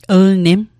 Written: lnim

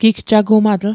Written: gigjagumatl

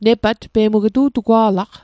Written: nepat

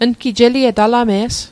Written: alame's

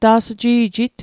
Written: ta's'ji'jit

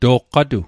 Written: toqqatua

